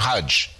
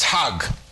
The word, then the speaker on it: Hajj: You call him a Hajj. Hajj is a person who goes to Mecca. In Egyptian Arabic, it's Hag. The J becomes a G. So it would be H-A-G or C-H-A-G.